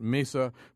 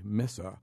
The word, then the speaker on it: Mesa: Locals pronounce it Mesa, but many Spanish speakers and natives do say Mesa.